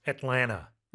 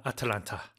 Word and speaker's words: Atlanta: And pronunciation, usually it's just pronounced Atlanta. Right, instead of Atlanta. – 그렇죠. 이건 한국식 발음이니까. – Yeah. – 네. Atlanta, 뭐 미국식으로 Atlanta. 그래서 세 개 음절이에요. 우리는 Atlanta, 사음절로 한국식으로 하지만 영어로는 삼음절. 자, 근데 여기서 Ted Turner saw himself in Atlanta 하셨어요.